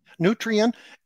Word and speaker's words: Nutrien: And so, how do you pronounce the company? Nutrien, is it? – Nutrien